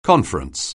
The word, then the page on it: conference: anglais